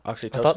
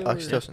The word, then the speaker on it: oxytocin: Whereas oxytocin, am I saying that right? – Oxytocin? – Oxytocin. Yeah. That's what I thought. I was thinking OxyContin. I was like, no, oxytocin.